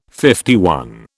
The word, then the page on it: LI: British English